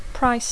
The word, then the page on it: price: English